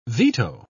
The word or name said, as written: veto